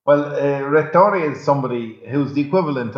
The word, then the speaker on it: Rettore: Am I right in saying that your official title in the university there is Rettore? Is that the correct pronunciation? – Rettore, but if you want to give me my full title, it's Magnifico Rettore.